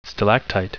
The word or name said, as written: stalactite